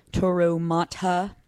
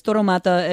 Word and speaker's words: Toro Mata: Toro Mata is the way I would pronounce it in American, but it's Toro Mata.